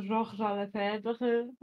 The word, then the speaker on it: Georges Lefebvre: Georges Lefebvre, uczę się francuskiego, więc będę go tak wymawiać, e, który jest taką bardziej szkołą marksistowską, zresztą zbazowaną bardzo, a rewizjonistami, którzy, którzy się z nim zupełnie nie zgadzają.